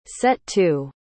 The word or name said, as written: set-to